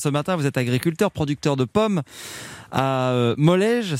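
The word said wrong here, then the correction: Mollégès: c'est ça, je le prononce bien ? – Molégès, pardon. Mais oui, voilà, Molégès.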